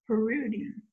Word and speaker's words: Parrouty: But if you want to, it's pronounced Parrouty. – Parrouty. – Parrouty, yeah. It's French, but it's a really weird, like, obscure French name. – So. Ooh, nice. – Seriously, yeah. – Parrouty.